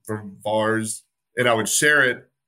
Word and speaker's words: VAERS: I said that while ago, but I want to talk about VAERS, or you pronounce it VARS, V-A-E-R-S.